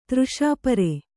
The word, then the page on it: ತೃಷಾಪರ: třṣāpara